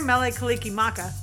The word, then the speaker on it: Mele Kalikimaka: I hope I get to hear Mele Kalikimaka.